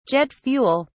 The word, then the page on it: jet fuel: British English